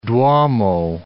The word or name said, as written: duomo